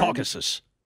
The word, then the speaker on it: Caucasus: The Asia-Europe boundary is the, I do not know how to pronounce this, Caucasus watershed? – Caucasus.